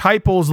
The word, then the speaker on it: Heupel: But I mean, they murdered Tennessee, murdered, Josh Heupel's looking at, like, rhymes with hype, as in the hype train,